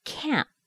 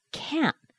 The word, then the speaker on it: can't: Do you hear the T? It's not very strong, is it? Sometimes it's difficult to even hear it. You get the T from that sharp stop. Can't. Can't. Now, this sharp sound at the end is one of the clues to hearing the difference between can and can't.